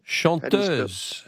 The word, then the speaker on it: chanteuse: Chantress. – Chanteuse. – How do you spell that? – Chanteuse, a chanteuse. It's a French word for a singer.